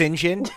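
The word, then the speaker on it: St. John: It's pronounced Sinjin.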